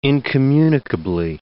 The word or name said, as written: incommunicably